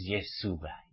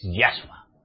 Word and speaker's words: Yahshua: Como estos mesiánicos que decís que es Yeshua, Yeshua o Jesúa. ¿Cómo hay que pronunciarlo? ¿Yeshua o Jesúa? Pues no lo es. Porque es Yahshua.